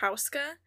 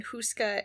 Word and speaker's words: Houska: So that's one of the pronunciations the most common one that I found, but there are other pronunciations that are Hauska. But I feel like it's in um, the Czech Republic, and I feel like for some reason Hauska is Americanized and Houska is how they would pronounce.